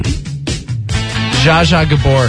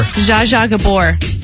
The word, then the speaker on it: Zsa Zsa Gabor: Zsa Zsa Gabor. – Zsa Zsa Gabor.